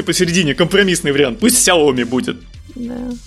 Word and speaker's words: Xiaomi: Нам тут пишут в чатике, что правильно произносить Xiaomi.